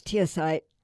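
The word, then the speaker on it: teosinte: 10,000 years ago, in what's now Mexico, for instance, farmers took a weed called tesamite and created corn. I mispronounced that. It's teosite, since tiny ears contain only about a dozen kernels.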